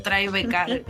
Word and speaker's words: Tribeca: Eso, Tribeca.